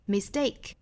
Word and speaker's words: mistake: Complains that you have made a mistake， made 连读 mistake 重读在后面。它的发音不送气，mistake mistake，